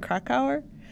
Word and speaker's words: Krakauer: The book Into the Wild by John Krakauer. I hope I say his name right. I feel like I say it wrong all the time. But you got it right.